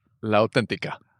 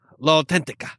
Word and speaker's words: L'autentica: L'autentica. – L'autentica.